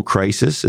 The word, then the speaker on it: crises: So I've lived through multiple crises. Is that, that's how you say it? – Yep. – It's like moose and fish. – It's like moose and meese. – Moose and meese.